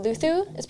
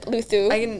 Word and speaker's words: Luzu: And then Spanish, you got I think you pronounce it Luthu. It's spelled Luzu, but Luthu.